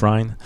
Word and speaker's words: Rhine: I always get Matt's last name wrong when I say it on the pod, but it rhymes with the river in Europe. – The Rhine. – Matt Rhine. – Make it Rhine.